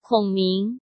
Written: Kong Ming

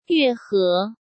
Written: Yue He

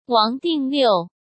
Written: Wang Dingliu

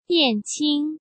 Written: Yan Qing

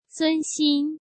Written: Sun Xin

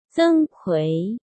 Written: Zeng Kui

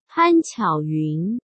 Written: Pan Qiaoyun

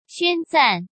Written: Xuan Zan

